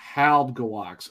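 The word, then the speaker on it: Halbgewachs: Halbgewax. Okay, so you say Jabberwocky? What? – so you put the H-A-L-B together, then you do the G-E-W-A-C-H-S.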